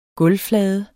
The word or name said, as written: gulvflade